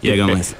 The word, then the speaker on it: Jägermeister: ¿Cómo sí, es Jägermeister.